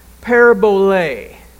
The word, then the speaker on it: parabole: It's spelled P-A-R-A-B-O-L-E, parabole, parabole.